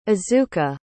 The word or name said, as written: azuka